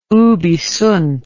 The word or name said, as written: ubi sunt